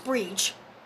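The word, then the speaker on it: Bríd: But it's pronounced Breach. – Breach. – Okay, so it's pronounced Breach. And it's B-R-I with the accent D. Breach. – Okay, yeah. – But the English variant is Bridget.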